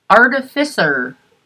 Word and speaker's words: artificer: He's promoted to artificer.